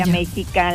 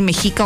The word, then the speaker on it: Mexica: Entonces se trata de una empresa militar creada por el presidente López Obrador y así se llama Olmeca, Maya y no sé si lo correcto sea decir Mexica o Mexica, ¿no? Ah, bueno, sí. ¿Verdad? – Sí, sí, sí, la X se pronuncia como J o como como tal, ¿verdad? Con X.